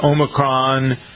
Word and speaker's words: Omicron: For those of you who didn't hear, I was calling Omicron. – Omicron, like a tech company.